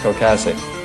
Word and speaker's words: Ocasek: I saw Stephen Colbert did a wonderful tribute to him, but he kept calling him Rick Ocasek. But then I go on YouTube to figure out how to say his name. And in this little video clip, he says, "Hi, I'm Rick Ocasek."